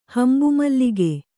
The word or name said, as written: ಹಂಬುಮಲ್ಲಿಗೆ